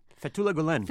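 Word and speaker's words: Fethullah Gülen: Fethullah Gulen? – Fethullah Gulen.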